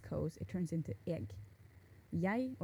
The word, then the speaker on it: jeg: or maybe like first a standardized word is jeg. And the pronunciation can vary a little bit, but it's spelled jeg. So it's like J-E-G.